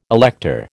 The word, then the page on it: elector: American English